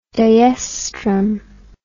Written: dioestrum

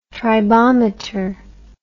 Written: tribometer

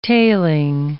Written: tailing

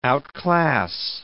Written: outclass

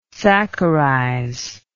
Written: saccharize

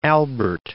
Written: Albert I

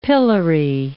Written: pillory

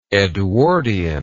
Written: edwardianism

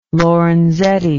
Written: lorenzetti

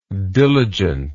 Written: diligentness

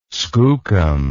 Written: skookum

